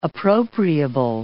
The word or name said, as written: appropriable